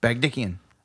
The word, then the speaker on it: Bagdikian: Bob Odenkirk plays Ben Bagdikian, Bagdikian, is that what he said? – That's how they pronounce it the whole movie. – Fair enough. – Bagdikian.